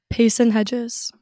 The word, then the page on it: Payson Hedges: female